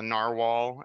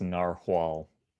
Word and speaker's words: narwhal: And I that resonates with me because I remember being like that when I was a kid, learning about the narwhal and that it was a real animal that looked like a unicorn. And, you know, I believe it's pronounced narwhal. – Narwhal, good point.